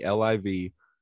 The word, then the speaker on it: LIV: LIV, like Club LIV in Miami? – That's the thing. It is spelled exactly like Club LIV in Miami, LIV.